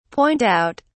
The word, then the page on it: point out: British English